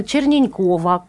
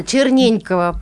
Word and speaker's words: Черненькова: А в гостях у нас Ольга Борисовна Черненькова. Правильно я произношу? Здравствуйте. Здравствуйте, Черненькова. А, Черненькова, пардон, так.